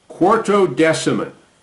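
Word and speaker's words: Quartodeciman: Quarto Deciman, and it's spelled just like it sounds, Quarto hyphen Decamin, Quarto Deciman.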